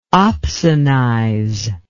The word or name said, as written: opsonize